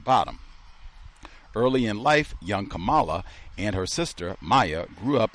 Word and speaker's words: Kamala: Biden's VP pick, why Kamala Harris embraces her biracial roots. So this is August 11, BBC. My name is pronounced Kamala, like the punctuation mark.